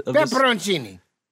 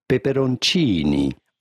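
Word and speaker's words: peperoncini: Pepperoncini. – Pretty close. Let's hear it, Brad. – Pepperoncini.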